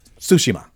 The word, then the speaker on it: Tsushima: Said, I thought you sorry, said Toshiba, sorry, and sorry, I was sorry. Like, Ghost of Toshiba? – I'm not saying it right. Surprise to everyone. Tsushima.